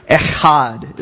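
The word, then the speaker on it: echad (אחד): It's this Hebrew word, echad, is how you say it. E-C-H-A-D is how you would literally kind of write it out.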